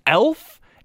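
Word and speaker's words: e.l.f.: She said it's pronounced elf.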